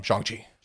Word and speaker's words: Shang-Chi: On Shang-Chi. Correct pronunciation. I corrected myself.